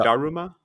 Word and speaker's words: Daruma: Am I saying it right? Daruma or da- Daruma? Da- Daruma, yeah. Daruma.